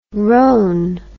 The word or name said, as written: rone